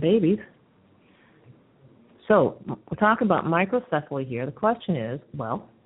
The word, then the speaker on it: microcephaly: They even tell you how to pronounce it. It's microcephaly.